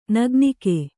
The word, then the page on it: ನಗ್ನಿಕೆ: nagnike; kannada